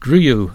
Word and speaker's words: Griou: His new one is called Griou. That's the correct pronunciation, not the one I gave the other week.